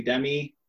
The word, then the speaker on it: Udemy: I did a, I don't know if I'm pronouncing it correctly, Udemy course.